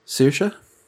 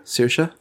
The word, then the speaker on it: Saoirse: Saoirse? – Saoirse? – Saoirse. – Okay, I'm not going to get that for the entire Saoirse thing. Ronan? – I'll tell you that right now. Saoirse.